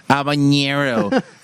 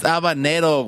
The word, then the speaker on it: habanero: Like, I hate when people go like habanero. It's habanero, güey. Fucking get it right.